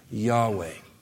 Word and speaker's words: Yahweh: We pronounce YHWH Yahweh.